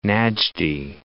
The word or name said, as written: Najdi